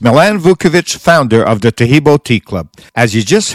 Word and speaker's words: Taheebo: Taheebo is spelled T-A-H-E-E-B, like boy, O, then the word tea, and then the word club.